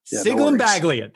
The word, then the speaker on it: Sciglimpaglia: Sciglimpaglia.